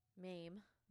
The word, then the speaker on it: Mayme: I believe it's Mamie, or it's Mame or Mamie. M-A-Y-M-E. – Mame. Sounds like Mame.